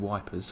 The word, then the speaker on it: Ypres: We went to a place called Ypres, which apparently is what the soldiers called Wipers, because it's spelled Y-P-R-E-S.